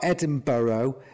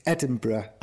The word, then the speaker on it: Edinburgh: It's not Edinburgh. It's Edinburgh.